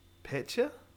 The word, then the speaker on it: Paetsch: Hans Paetsch. It's spelt Paetsch. – Petcher? – Petcher? – Yeah, okay. Shirt would be Petcher, I think.